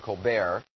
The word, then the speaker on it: Colbert: What? Colbert? Really? Are you sure? The T is silent. Stephen Colbert, apparently.